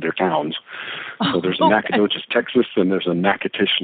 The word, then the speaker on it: Nacogdoches: yes, horribly. It's pronounced Nacogdoches,